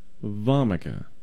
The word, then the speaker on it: Vomica: It's called Nux Vomica. – Okay. – That's spelled N-U-X, and the second word is V-O-M-I-C-A. It's almost like the word vomit.